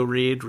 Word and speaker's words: Reid: Reid is spelled R E I D. I am also on Letterboxd, uh, Joe Reid.